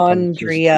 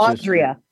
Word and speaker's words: Andrea: I've got alongside me today Dr. Andrea Adams Miller, my fabulous Andrea. Publicist. I've been teaching Frank how to say my name. – I know. – It's Andrea. Andrea.